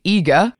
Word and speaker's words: Iga: Iga.